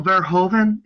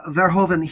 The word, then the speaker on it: Verhoeven: Paul Verhoeven, am I pronouncing yes. his name right? Yep. – Mm-hmm. Uh, Verhoeven, he, I mean, that film to me speaks of his style, you know, very much.